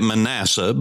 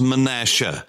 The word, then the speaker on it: Manasseh: That's the way you say it. We say it Manasseh, but it is Manasseh. S-H-E-H is the way it would phonetically be spelled.